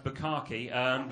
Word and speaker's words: bukkake: Buckcake. – What was that? – Buckcake. – It's pronounced bukkake, but we will...